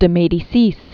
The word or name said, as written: Marie de Medicis